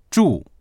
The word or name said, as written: zhu4